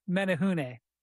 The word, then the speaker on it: Menehune: Menehune.